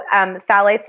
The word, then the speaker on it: phthalates: So phthalates, while we're on it, those are a big one.